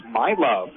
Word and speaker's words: Miluv: M-I-L-U-V is how she spells her first name. And so I asked some of the parents here, help me with the pronunciation. It's my love.